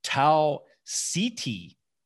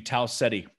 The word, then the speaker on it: Ceti: He pronounced it Tau CT prime. Where it's spelled CETI, it should be Tau Ceti prime, right?